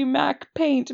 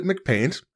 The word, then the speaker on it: MacPaint: I always pronounced it McPaint, and I was berated multiple times in my childhood by Mac users who were like, no, it's Mac Paint.